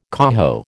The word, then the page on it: coho: American English